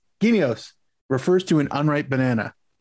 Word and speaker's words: guineos: It's spelled G-U-I-N-E-O. And it looks like it's pronounced Guineos.